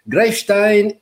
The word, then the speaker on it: Greifstein: Lovely greets to Sib from Greifstein in Mittelhessen. Sorry for German pronunciation. – Sounded almost right, actually.